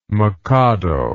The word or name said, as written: mockado